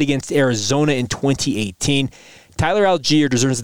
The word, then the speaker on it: Allgeier: So not great numbers, but numbers that won the game because Tyler Allgeier. And yes, I just made his name three syllables long because this man was an absolute dude.